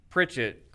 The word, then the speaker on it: Pritchett: But, I mean, my last name's Pritchett, Kwaku, and imagine my childhood with all the words that rhyme with Pritch, for example.